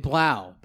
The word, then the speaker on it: Blough: David Blough, because I have to remember that it rhymes with bow, as in when the bow breaks, because I made that hideous pun.